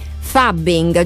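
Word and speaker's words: phubbing: Il nome forse è difficile, perché parleremo di fubbing, giusto? Pronuncio bene? Sì.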